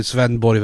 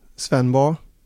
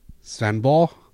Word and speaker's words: Svendborg: Alltså Max sa ju Svenborg väldigt svenskt. Jag vill höra ett mer danskt uttal. Svenborg. Svenborg.